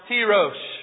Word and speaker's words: tirosh: Third word, Hebrew word, pronounced tirosh. Tirosh. And there's some and stuff in there. I'm just not good at it, okay? But just know it's in this Wilmer accent, it's tirosh.